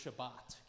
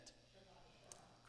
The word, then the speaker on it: Shabbat: The word here in Genesis for rest is pronounced Shabbat. Can you say that? Shabbat.